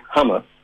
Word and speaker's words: hummus: Maravillosa, y la pronunciación es hummus.